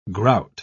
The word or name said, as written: grout